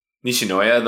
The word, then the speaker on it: Nishinoya: Maybe it's Nishina? Nishinoyo? How do you pronounce it? – Nishinoya, the libero? – The libero.